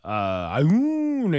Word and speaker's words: Aune: It just doesn't make sense in 2024, said Representative Ashley Dune from Missouri. Uh, Aoun, I said, June, what's her name? Aune. A-U-N-E. – How would you pronounce that? A-U-N-E. – Aune. Werewolves of London.